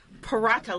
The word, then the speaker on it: paratha: Well, I've had a great paratha lesson, and that is spelled P-A-R-A-T-H-A. So the H is silent.